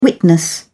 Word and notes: witness: British English

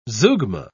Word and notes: zeugma: British English

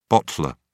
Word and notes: bottler: British English

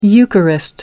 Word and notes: eucharist: American English

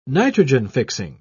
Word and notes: nitrogen-fixing: British English

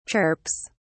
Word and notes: chirps: British English

